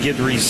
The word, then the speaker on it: Guidry: And this year when we get here, look at the pronunciation guide, and next to his name it says G-I-D-G-E-R-E-E, as if you should be pronouncing it Guidry.